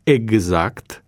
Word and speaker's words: exact: Dar vom pronunța exact sau examen sau exercițiu, adică cu G și Z, deși am scris cu X.